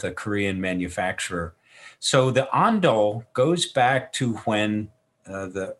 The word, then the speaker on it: Ondol: So, are we saying it right? – Yeah, you're saying it correctly. So, Ondol.